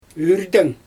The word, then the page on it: yrdɛŋ: Xyzyl